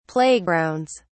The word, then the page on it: playgrounds: British English